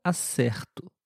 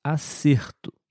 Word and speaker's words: acerto: A escrita é a mesma, porém, na primeira frase eu disse acerto e na segunda frase eu disse acerto. O som é um pouquinho diferente, especialmente na letra E.